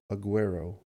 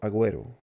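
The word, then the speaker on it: Agüero: And it was also cool to see Sergio Aguero. Why did I say his name? Spanish first name, and then I went not Spanish. Sergio Aguero.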